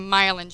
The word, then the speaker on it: myelin: And it's not mile in as in M-I-L-E-I-N. It is mile in, like the myelin sheath. And that is spelled M-Y-E-L-I-N.